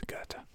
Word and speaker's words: Goethe: Is that how you say his name? I didn't know that's how you said it. – Yeah, it's Goethe.